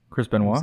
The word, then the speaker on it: Benoit: Like obviously like the WWE has a big, don't mention that anybody that knows Chris, Chris Benoit.